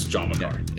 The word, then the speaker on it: John Macari: Yes, John Macari.